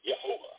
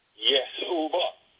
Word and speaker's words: Yehovah: Yehovah. Using English letters, you would spell it according to its pronunciation, Y as in the year, E-H-O-V as in Victor, A as in Adam, H as in Howard, Yehovah.